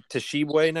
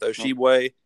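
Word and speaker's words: Tshiebwe: I think we've learned it might be Toshibwe now. We've heard multiple people. Dockage was going with Oshibwe Sunday. We heard Oshibwe. – What do you do? What do you say? How do you pronounce the guy's name? – I think it's just Shibway. – I'm thinking you're right.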